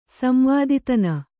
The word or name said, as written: ಸಂವಾದಿತನ